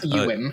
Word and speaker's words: Ewan: It's Ewan, uh, Ewan McGregor. – Did I butcher that name Not too? Ewan. Ewan. Are we? Are we? Ewan right McGregor.